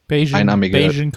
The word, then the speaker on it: Bayesian: ich weiß nicht, wie spricht man die aus? Bayesian Curls? – Bayesian Curls, ja.